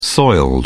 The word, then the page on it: soiled: British English